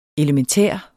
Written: elementær

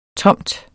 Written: tomt

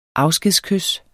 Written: afskedskys

